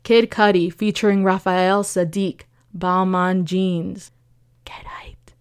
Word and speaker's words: Balmain: So I looked it up, and apparently it's Balmain Jeans. I mean, Balmain. But the French don't really pronounce the N at the end. And the reason for that is because the French don't really pronounce the N's when it's attached to a vowel. So Balmain is B-A-L-M-A-I-N. A-I-N. So I guess that's why the N kind of disappears.